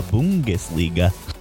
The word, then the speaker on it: Bundesliga: The Bundesliga.